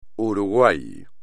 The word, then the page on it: Uruguay: espagnol